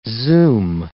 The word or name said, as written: zoom